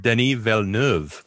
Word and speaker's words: Villeneuve: how do you pronounce his last name? Because I was looking at it and she's like, is it Villeneuve? And I'm like, no, but he's French Canadian. So would it be like the French Canadian pronunciation of that? And then I looked it up in the internet, blah, blah, on the internet, and it's just Denis Villeneuve. – What? – Yeah. I've been saying it wrong.